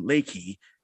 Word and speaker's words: Reiki: I I do fitness too, and also um, you do something called um, Reiki or Reiki or or in Japanese um pronunciation, it's um Reiki, which is um a form of um energy healing.